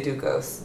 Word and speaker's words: Ducos: So when I say my name and I introduce myself, I'm like, hi, my name's Lindsay Ducos. I say it like that, and then people are like, is Ducos? And I'm like, just say Ducos, because it feels more comfortable for me to respond to that, because that feels like almost nurturing, almost like we're friends.